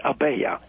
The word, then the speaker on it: Abella: The name's Abella. – Abella. – It's spelled Abella, but it's pronounced Abella.